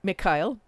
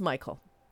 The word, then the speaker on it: Michael: the Slav in me wants to pronounce it Mikhail, but it's Michael, with Niven Pauly.